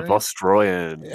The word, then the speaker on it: Vostroyan: The Volstrayan, is it Volstrayan? – The Volstrayan.